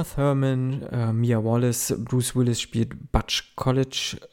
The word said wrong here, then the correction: Coolidge: Äh, Butch, Butch, Butch Coolidge, Butch Coolidge, so spricht man es aus.